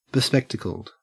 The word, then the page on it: bespectacled: Australian English